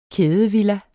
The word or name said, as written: kædevilla